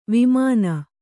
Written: ವಾಮನೆ

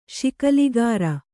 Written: ಶಿಕಲಗಾರ

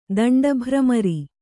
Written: ದಂಡಭ್ರಮರಿ

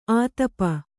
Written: ಆತಪ

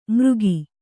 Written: ಮರಗಿ

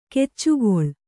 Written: ಗಿಚಕಳಿ

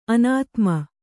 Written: ಅನುತ್ತಮ

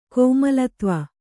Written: ಕೋಮಲತ್ವ

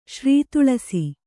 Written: ಶ್ರೀತೊಳಸಿ

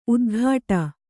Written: ಉತ್ಕಟ

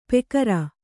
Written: ಪೆಕರ

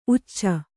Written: ಉಚ್ಚ

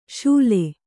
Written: ಶೂಲೆ

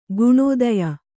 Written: ಗುಣಾಧೇಯ

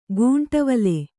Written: ಗುಂಟವಲೆ

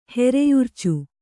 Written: ಹೆರೆಯುರ್ಚು

